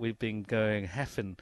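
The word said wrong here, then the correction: Hefin: so it is Heaven.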